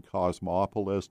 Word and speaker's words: Cosmopolis: We found it in Cosmopolis. Did I pronounce that right? – That's correct. Yes.